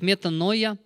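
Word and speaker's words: метаноя (μετάνοια): Слово «покаяние» по-гречески означает, произносится как «метаноя» и означает «перемена мыслей покаяния».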